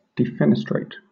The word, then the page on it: defenestrate: British English